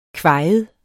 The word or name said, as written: kvajet